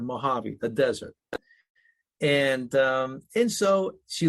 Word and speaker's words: Mojavie: She goes, you know, Dad, if you took Mojave, just slap an I in front of the E, it'd be pronounced Mojave.